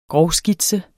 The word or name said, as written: grovskitse